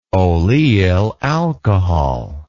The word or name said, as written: oleyl alcohol